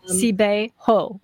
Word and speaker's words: Sabeho: So the emphasis is on the ho, right? – Actually, basically, Sabeho is three Chinese characters. So Sabeho is... – Sabeho.